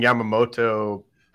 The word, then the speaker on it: Yamamoto: But uh, so you you were saying Yamamoto. Am I saying that right? – Yes, Yamamoto Yoshinobu.